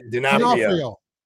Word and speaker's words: D'Onofrio: And this character is played by Vincent D'Onofrio, if I'm not... – D'Onofrio.